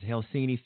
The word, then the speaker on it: Helsinki: In Helsinki, that's the way you pronounce it, Helsinki, Finland, on stage with Vladimir Putin.